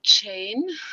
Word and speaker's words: Jean: Ich heiße oder werde ausgesprochen als Jane, J-A-N-E. Meine Eltern haben sich leider verschrieben und haben mich zu einer Jean gemacht, also J-E-A-N. Aussprache war aber immer Jane, aber das viel Schlimmere war, die andere Schreibweise ist quasi der französische Jean.